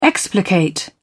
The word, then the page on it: explicate: British English